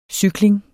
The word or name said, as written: cykling